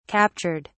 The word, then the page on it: captured: British English